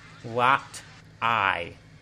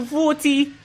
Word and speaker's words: roti: Oh, dear lord, what is a rot eye? It is a rooty. That's what we call it. You say rooty, understand? – How do you say it? – Rooty. R-O-T-I. Rooty.